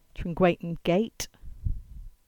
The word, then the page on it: Trengwainton Gate: English